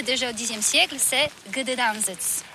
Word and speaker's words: Gdańsk: Alors d'abord, comment prononce-t-on bien? Ça s'écrit G-D-A-N-S-K. On dit Gdańsk ou Dansk, on prononce le G ou pas?